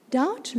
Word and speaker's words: doubt: That silent B. Doubt lurks, and it lurks in all of us like sin.